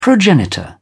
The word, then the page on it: progenitor: British English